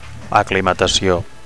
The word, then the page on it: aclimatacio: Catalan